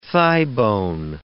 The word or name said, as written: thighbone